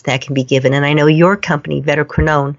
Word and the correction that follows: Vetoquinol: am I pronouncing it correctly? – Yeah, Vetoquinol. Yep. – Vetoquinol.